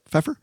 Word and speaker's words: Pfeiffer: Is that how you pronounce that? Pfeiffer? – Yes.